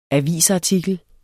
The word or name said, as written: AVISARTIKLER